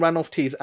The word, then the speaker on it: Ranulph Tees: So, you know, your name, Ranulph Tees, am I pronouncing it correctly? – Yeah, that's absolutely right.